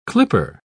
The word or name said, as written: clipper